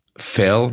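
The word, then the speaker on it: Verl: Junge aus Filderstadt, der sich letzte Woche gegen Ferl, mit Betonung auf F, eine rote Karte eingefangen hat und zwei Spiele in der dritten Liga jetzt zugucken muss.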